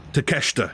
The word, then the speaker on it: Takeshita: Was it Takeshita? – Takeshita. Yeah.